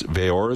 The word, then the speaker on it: Veau: His name is, it's spelled like Christian Veau, but it's Veor, is that right? – Veor is how he pronounces it, yeah.